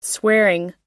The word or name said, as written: swearing